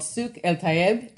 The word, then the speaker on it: Souk el-Tayeb: So Kamal created the first farmer's market in Beirut, Lebanon, which is called Souk el-Tayeb. Did I pronounce that one right? – That's perfect. Bravo.